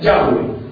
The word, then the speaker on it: Yahweh: En otras Biblias, ya ven, la pronunciación más correcta podría ser Yahweh.